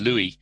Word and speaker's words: Louis: Just for clarity, my name is Louis. – Sorry. – I know you Americans tend to pronounce it Louis. – Yeah, we do that. Sure. That's our fault. Sorry. – it's a French name, and they don't pronounce the S in the end.